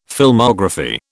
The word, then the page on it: Filmography: British English